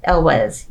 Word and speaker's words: Elwes: Yeah, and then afterwards, the person who plays Wesley, Carrie Elwes, I think is how you say his name. Elwes, yeah.